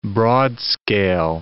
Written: broadscale

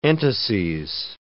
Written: entases

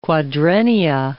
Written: quadrennia

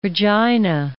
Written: Regina

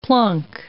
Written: plunk

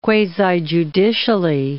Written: quasi-judicially

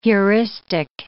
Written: heuristic

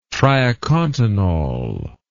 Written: triacontanol

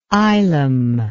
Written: ylem

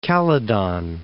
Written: Calydon

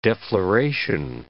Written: defloration

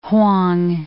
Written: Hwang

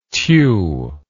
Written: Thieu

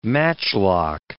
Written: matchlock

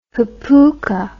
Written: hapuka

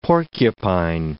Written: porcupine

